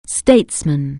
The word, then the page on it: statesman: anglais